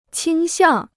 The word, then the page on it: 倾向: qīng xiàng